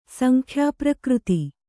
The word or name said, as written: ಸಂಖ್ಯಾಪ್ರಕೃತಿ